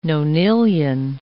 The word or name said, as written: nonillion